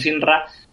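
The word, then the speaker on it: Shinra: Sinra, creo que se pronuncia así, sí. – Ya sabes, a la española, siempre a la española.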